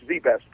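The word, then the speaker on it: ZZZZ Best: While still in high school, he founded, is it 4Z or just ZZZZ? 4Z Best, right? – No, it's Z Best. It's just Z Best, pronounced Z Best.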